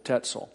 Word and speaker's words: Tetzel: I used to tease Jim Wetzel incessantly that the Lutheran minister's name rhymes with Tetzel.